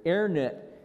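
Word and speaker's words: Herrnhut: 2 weeks later, on August 27th, on a Wednesday, the community, they were called the Hairnet community. I'm not saying it right, CJ. Airnet community, which means the Lord's watch, began to pray.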